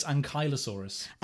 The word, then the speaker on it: Ankylosaurus: Because to me, it's Ankylosaurus. – Yeah, I thought it was Ankylosaurus for the longest time, but apparently it's Ankylosaurus. – Like Kylo Ren.